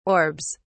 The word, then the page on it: orbs: British English